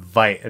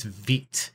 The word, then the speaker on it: Vite: I've been pronouncing wrong forever. I've been calling it Vite. And my, my friend pointed out recently to me it's not Vite, it's Vite, it's French.